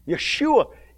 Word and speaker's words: Yeshua: Yeshua. Yes, there's the Hebrew pronunciation. Yeshua, He will come to save His people.